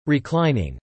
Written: Reclining

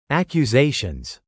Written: accusations

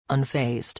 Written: unfazed

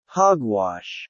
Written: hog-wash

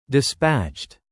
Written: dispatched

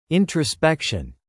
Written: introspection

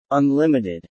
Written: unlimited

